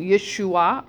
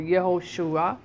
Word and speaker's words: Yeshua: Yeshua or Yehoshua, two different pronunciations for the same word, salvation.